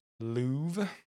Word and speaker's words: Louvre: I'm pretty sure it's the Louvre. If we're wrong, let us know in the comments. Okay. Anywho. – The R is silent.